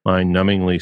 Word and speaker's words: numbingly: Sometimes it's all forest, too general, and sometimes it's all trees, too mind-numbingly specific. I think I shouldn't have pronounced the B in numb, right? Mind-numbingly specific.